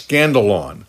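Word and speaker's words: scandalon (σκάνδαλον): It's the Greek word you would pronounce scandalon. And guess what word we get from it? We get the word scandal. The English word scandal is from this Greek word scandalon.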